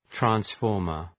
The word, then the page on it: transformer: træns’fɔ:rmər